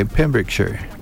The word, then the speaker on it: Pembrokeshire: In how do you say that? Pembrokeshire? Okay, Pembrokeshire. Am I close? – Yep.